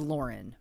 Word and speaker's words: Lauren: But I do also want to add very quickly, my name is Lauren.